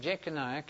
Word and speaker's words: Jeconiah: J-E-C-O-N-I-A-H.